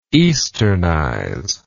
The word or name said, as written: easternize